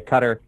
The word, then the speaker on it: Qatar: But I am going to rely on Jonathan Greenberg's Middle East expertise here to say Qatar.